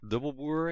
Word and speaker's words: Dubbelboer: I'm Eric Dubbelboer. You pronounced it almost correctly. I'm Dutch, so really Dutch last name.